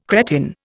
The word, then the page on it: cretin: Indian